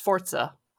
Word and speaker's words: Forza: It's also Forza? – What? – Forza. – Forza? – Not Forza. Forza. – Forza? – It's Forza.